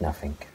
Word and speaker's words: nothing: So what should be a G, you put in a K. Nothing. It should be nothing. Nothing. Nothing. There's nothing at the end of that. Sound. There's no K there.